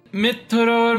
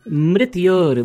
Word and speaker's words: mrityor: Mitror mushki. – Mitror mushki. No, it is mrityor mukshiya. Mrityor. Yes. Mrityor.